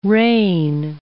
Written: rain

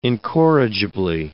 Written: incorrigibly

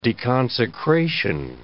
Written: deconsecration

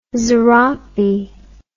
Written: zarape

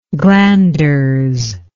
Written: glanderous

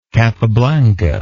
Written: Capablanca

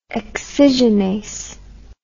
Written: excisionase

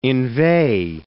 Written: inveigh

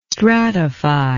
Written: stratify

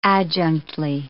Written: adjunctly

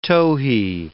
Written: towhee

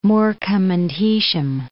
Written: Morecambe and Heysham

